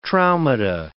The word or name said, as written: traumata